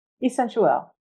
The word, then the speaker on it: Escentuelle: A scent artist, jeweler, and she is the founder of Essentuel. Am I saying that right? – Essentuel, yes. – Essentuel, okay. And she lives her passion by empowering women to pursue what they truly desire in life.